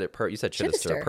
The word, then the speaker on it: Chidester: And you said Chidester perfectly.